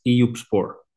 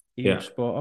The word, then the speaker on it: Eyüpspor: You would pronounce it as Eyüpspor. – Eyüpspor, okay.